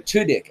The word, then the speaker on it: Tudyk: Tudyk. Alan Tudyk. – Yes, Tudyk.